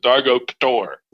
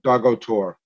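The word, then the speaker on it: Dargo Ktor: Yeah, how do you pronounce the K and a T together? Is one of them silent, or do you pronounce it Dargo K'tor? – Yeah, I was pronounced it Dargo Tor. – So the K is silent.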